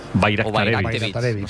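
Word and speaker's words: Bajraktarević: Bayraktarevich. O sea, la J la conviertes en ahí, Bayraktarevich.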